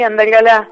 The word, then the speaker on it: Andalgalá: Yo eh, lo pronuncio bien o mal, vos me corregís. Andalgalá es. – Sí, Andalgalá, este está muy bien.